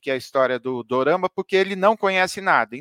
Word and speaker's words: dorama: O termo dorama vem da pronúncia japonesa da palavra drama.